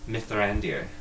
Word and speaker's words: Mithrandir: Mithrandir. – I like the R he's doing on that. – It's got an accent on the I.